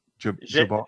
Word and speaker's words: Javert: Javert.